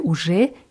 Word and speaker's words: уже: Ты уже с ней познакомился bei уже ist die Betonung auf dem je.